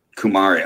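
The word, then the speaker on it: Kumare: It's K-U-M-A-R-E. – Kumari.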